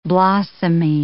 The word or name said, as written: blossomy